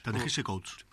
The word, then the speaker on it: Ta-Nehisi Coates: Voy a empezar por Black Panther, va a estar escrita por Ta-Nehisi Coates. Uh-huh. ¿Cómo se dice? Coates. – Ta-Nehisi Coates.